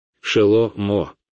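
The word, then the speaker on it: Шело-мо (שלמה): Его настоящее имя — Шелома, но вы, ребята, к сожалению, называете его Соломоном. Но я говорю о том, что когда я произношу его имя Шелома, вы, ребята, знаете иврит, когда я говорю Шелома, вы слышите это слово. Шело-мо. Шело-мо.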